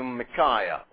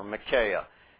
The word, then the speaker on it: Micaiah: He appears to Micaiah, or Micaiah, depending on if you want to pronounce things in a British way or an American way, respectively.